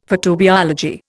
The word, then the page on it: photobiology: Indian